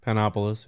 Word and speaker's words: Panopolis: And coincidentally, this comes from notes about your homeboy Zosimos of Panopticlis. I can't always pronounce it. – Panopolis, yeah.